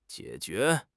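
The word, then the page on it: jie3 jue2: tone 3; tone 2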